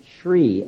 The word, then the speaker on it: Shri: Is Shri, S-H-R, Shri. You could phonetically spell it S-H-R-E-E. It's usually spelled S-H-R-I, but it's Shri.